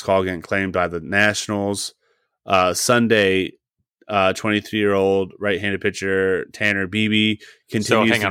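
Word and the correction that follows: Bibee: I got information that it's pronounced Bybee, and I would have Bybee? Never known that. Yep, it's Bybee.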